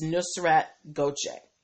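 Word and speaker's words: Nusret Gökçe: But when I looked up the pronunciation, it's Nusrat Goche.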